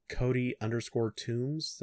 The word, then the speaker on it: Toombs: You can find me at Cody_Toombs.